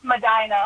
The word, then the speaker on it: Medina: If it's supposed to be pronounced Medina, I apologize. I never get those right. – Yeah, it's pronounced Medina. – Nah! – Medina. – Yeah, like a normal person, but not a person from Ohio.